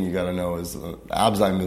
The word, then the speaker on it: Abzyme: We are joined today by the founder of the Abzyme Research Foundation, correct? – Thank you for pronouncing that correctly.